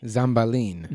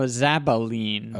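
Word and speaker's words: Zabbaleen: Zambalin. – No, Zabalin. – Oh, Zambalin sounds like an instrument. – Yeah, there's no M in there.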